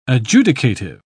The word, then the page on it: adjudicative: British English